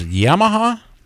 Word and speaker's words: Yamaha: Sea-Doo, Can-Am Beta, KTM Polaris, and of course, is it pronounced Yamaha? – That's it. Nailed it.